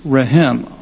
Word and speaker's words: rehem: And it's and as you look at it, the word is rehem, R-E-H-E-M, R-E-H-E-M. And it's related to a word that's almost spelled the same called raham, R-A-H-A-M. So you have rehem, which means mercy, mercy, merciful, and you have raham.